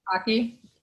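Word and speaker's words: Baci: So in Italian, I was told that kisses is Baki in Italian.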